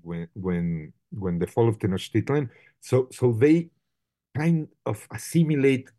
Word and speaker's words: Tenochtitlan: That is, we in Spanish, it's Tenochtitlan, which is hard to say, because it has the stress at the end, has something unsatisfactory about it. But if you pronounce it in Nahua, it's just incredibly easy to say and beautiful, no? Tenochtitlan. You just move the stress and that terrifying X that is all over, you know, the Nahua.